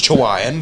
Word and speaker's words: Chihuahuan: It's not Chihuahua, but it's Chihuahuan.